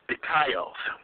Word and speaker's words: dikaios: at the word righteous in the Greek, it is pronounced dikaios, D-I-K-A-I-O-S.